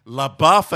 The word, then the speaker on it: LaBeouf: LaBeouf, as he tells us to pronounce it.